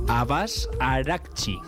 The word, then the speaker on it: Abbas Araghchi: Abbas Araghchi.